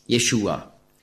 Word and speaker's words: Jeshua: Und Jesus ist einfach, ist also keine Übersetzung, sondern einfach die griechische Aussprache von Hebräisch Jeshua.